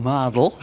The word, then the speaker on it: Mardle: Okay, so from the Oldham Chronicle newspaper, and I'm here with Wayne Hawaii 5-0 Mardle.